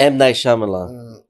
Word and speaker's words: Shyamalan: But I'm going to say his name wrong. Mount Night Shyamalan. – M. Night Shyamalan.